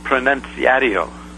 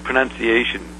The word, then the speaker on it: Pronunciatio: Then the final presentation or delivery of the thing you want to be effective was called Pronunciatio, like pronunciation, P-R-O-N-U-N-C-I-A-T-I-O, Pronunciatio, okay? – Mm-hmm.